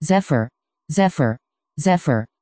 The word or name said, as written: zephyr